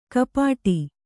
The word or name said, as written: ಗುಪ್ಪಟ್ಟೆ